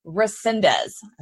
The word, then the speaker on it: Resendez: Resendez.